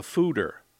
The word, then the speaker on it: foeder: The fooder. That's spelled F-O-E-D-E-R.